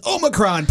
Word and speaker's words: Omicron: How are you pronouncing that one? – Omicron. – How about you, Matt? How would you say it? – It is Omicron, per CIH, just like Futurama.